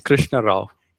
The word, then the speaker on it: Krishnarao: What is your last name? Say it again, because I couldn't pronounce it. – It's Krishnarao.